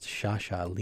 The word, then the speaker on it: Sciascia: Well, I found out from a friend who has actually read The Gentleman in the Italian that, in fact, his name is Sciascia. It's pronounced Sciascia, Leonardo Sciascia. So for anybody out there who's saying Sciascia, you should be saying Sciascia.